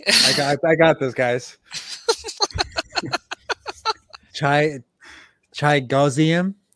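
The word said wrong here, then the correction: Chigoziem: I do know how to pronounce that name. It's Chigozim Okonkwo.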